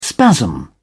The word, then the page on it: spasm: British English